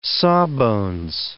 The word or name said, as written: sawbones